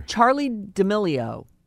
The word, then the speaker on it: Charli D'Amelio: Charli D'Amelio. Am I saying her name right? – Yeah. – So she is hot and has a zillion followers, and the kids love her. – Charli D'Amelio.